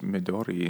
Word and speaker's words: Midori: I think it's just Midori, and you just flip the R sound.